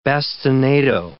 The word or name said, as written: bastinado